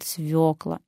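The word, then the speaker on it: свёкла: Конечно, правильный вариант – свекла. И только так. Вы можете сказать, что все говорят «свекла». Пусть говорят, как хотят, но мы должны с вами знать, что буква «ё» – показатель ударения. В слове «свекла» есть буква «ё». Мы ее не игнорируем, а просто правильно называем этот овощ – свекла, а суп – свекольный.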